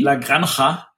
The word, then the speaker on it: La Granja: La Granja. Das, ich ich habe gehört, das spricht man wie das CH in Ach.